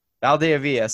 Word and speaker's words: Valdeavilla: Valdeavilla.